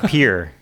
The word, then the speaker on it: Pierre: But it's pronounced Pierre.